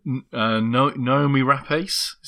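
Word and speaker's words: Noomi Rapace: She's dressed all in pink, and she's played by Naomi Rapace. Is that how you say her name? – It is.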